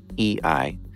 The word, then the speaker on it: eEye: It's spelled E-E-Y-E, EI.